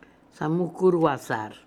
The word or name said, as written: Samookoorooazar